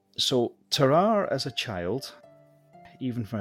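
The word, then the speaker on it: Tarrare: Terrar. – So it's T-A-R-R-A-R-E, Terrar. And I've watched quite a few videos on it, and people pronounce it different ways, but that seems to be the most common, Terrar.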